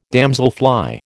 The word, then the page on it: damselfly: American English